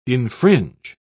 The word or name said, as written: infringe